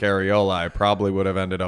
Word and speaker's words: Arreola: We got Adam Kownacki, Kownacki taking on Chris Arreola. I, I don't know if I'm saying that right. Yeah, yeah, you are. – It's okay. actually Arreola.